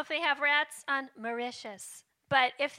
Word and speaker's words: Mauritius: Mauritius. – Wait, Mauritius.